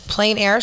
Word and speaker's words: plein air: plein air sketchers. Did I say that right? – Yes, that's right.